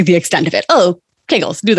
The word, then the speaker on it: Kegels: One of the things that I definitely had misconceptions about when I started PT was Kegels, which also I didn't know how to pronounce, but informed Kegels rhymes with bagels, which has been very helpful.